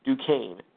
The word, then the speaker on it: Duquesne: Yes, it is pronounced Duquesne.